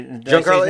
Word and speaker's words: Giancarlo: Giancarlo, yeah, that was it. I said it right. It was nice.